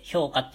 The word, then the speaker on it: 氷菓: 氷菓子って書いて評価って読むんですけれども、あの、もうこの作品がね、めちゃくちゃ、めちゃくちゃ大好きなんですよ、僕は。